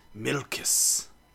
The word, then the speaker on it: Milkis: I'm trying to pronounce it like in native Korea. Milkis. – Is that... – Melon. – Yep, that's it.